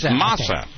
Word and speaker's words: masa: Which is just the mesa. I know I'm not pronouncing that properly. – I think it's masa. – Masa. – Masa. That's the Spanish pronunciation, isn't it? – Right.